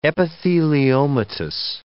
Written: epitheliomatous

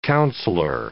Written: councillor

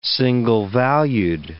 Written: single-valued

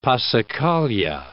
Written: passacaglia

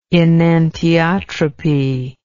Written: enantiotropy

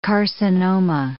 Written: carcinoma

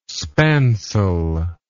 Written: spancel

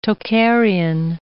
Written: Tocharian

